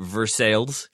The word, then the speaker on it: Versailles: Kentucky is the weirdest state for that I know of, because there is Baghdad, Kentucky, there is Lebanon, Kentucky, there is, this is their pronunciation, Versailles, Kentucky.